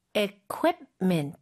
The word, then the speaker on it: equipment: Equipment. Equipment. – U 쪽에 강세가 많이 가고요. Keep이 아니라 keep을 다 발음을 하는데 부드럽게 하는 거죠.